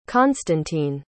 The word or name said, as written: constantine